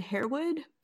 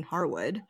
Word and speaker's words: Harewood: Yeah, so his name is David Harewood, but there's some other people that go by the pronunciation Harwood.